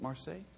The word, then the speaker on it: Marseille: in Marseille. Is that how you say it? Marseille? There? Okay.